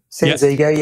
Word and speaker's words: Sansego: You know, you've got the, like you said, you've got the Sansego. Did I say that right? Sansego. – Sansego. Yeah.